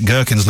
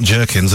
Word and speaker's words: gherkins: Of course, in Britain, we pronounce it gherkins, not jerkins.